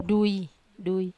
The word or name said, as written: dui i